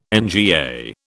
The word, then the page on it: NGA: British English